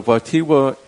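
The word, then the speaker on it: Wojtyła: We're speaking this morning on Real Presence Live with Peter and Teresa Martin, founders of the Watiwa Community and Institute. And if you want to know how to spell that, W-O-J-T-Y-L-A.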